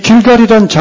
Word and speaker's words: 길갈: 이 길갈이란 장소는 굴려 없애다란 의미의 동사. 이 길갈은 그 히브리어로 이렇게 발음되는데, 길갈.